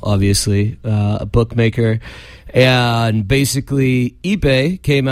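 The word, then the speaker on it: Ippei: And is that how you say his first name? – Ipe Mazuhara. – Yeah. I'm saying that right? – Ipe. Ipe.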